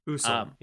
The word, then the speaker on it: Usul: But even like looking, I've mentioned like Desert Call and Usol or Usul, or however you pronounce that, you'd know better than me. – Usul, yeah.